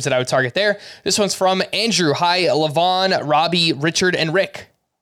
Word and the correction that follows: Levon: It's LeVon. That's how you pronounce this.